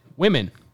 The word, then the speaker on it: women: The plural as written seems to be womb womb men, but we pronounce it women.